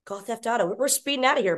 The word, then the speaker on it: Gaw Theft Auto: Our Gaw Theft Auto shirt. Am I saying that right? Gaw Theft Auto. – Oh, yes.